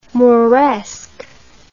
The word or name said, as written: mauresque